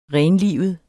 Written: renlivet

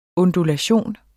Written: ondulation

